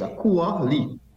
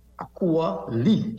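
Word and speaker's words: Akua Leith: Yes, Akua Lee. – Akua Lee. – Akua Lee, L-E-I-T-H.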